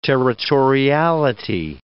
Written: territoriality

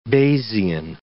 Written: Bayesian

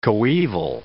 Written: coeval

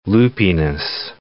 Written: loopiness

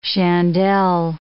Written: chandelle

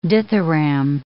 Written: dithyramb

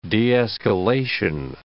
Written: de-escalation

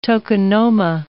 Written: tokonoma

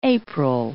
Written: April

